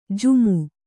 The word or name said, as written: ಜುಮು